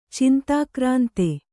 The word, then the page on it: ಚಿಂತಾಕ್ರಾಂತೆ: cintākrānte